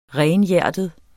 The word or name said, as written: renhjertet